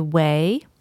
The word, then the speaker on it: Ouai: This episode is also brought to you in part by Way. Way is spelled O-U-A-I, and they are a skin care company.